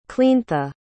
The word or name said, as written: cleantha